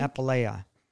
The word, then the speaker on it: apoleia: And the Strong's Concordance word is uh, Uh, how do you say that? Um, think apoleia,